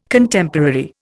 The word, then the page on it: Contemporary: Indian